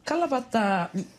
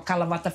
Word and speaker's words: Kalavata: So Kalavata, well, Kala, literally, well, is spelled K-A-L-A, but in the Fijian way.